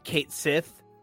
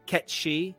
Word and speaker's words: Cait Sith: It's spelled C-A-I-T-S-I-T-H, Kate Sith, but it's pronounced Ketshi.